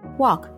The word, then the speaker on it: walk: We should, 我们应该 walk, 走路 on the sidewalk, 在人行道上面。这里要提醒爸爸妈妈们要注意 walk, 走路的发音与工作 work 是不一样的。